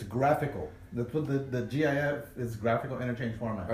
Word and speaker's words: GIF: So it's a hard G. – It's GIF.